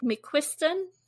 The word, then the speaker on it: McQuiston: I think that McQuiston, am I saying that right? – Mm-hmm.